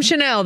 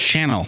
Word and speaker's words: Chanel: Well, okay, well, why don't you go get me some Chanel then, sir? – First of all, it's pronounced Chanel.